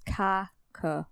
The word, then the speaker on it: kakapo: It's spelled K-A-K-A-P-O. And I had to listen to a lot of videos in order to memorize how to pronounce it because I wanted to say cockapoo, like, or, yeah. But it's cockapoo.